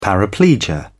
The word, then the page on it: paraplegia: British English